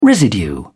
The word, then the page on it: residue: British English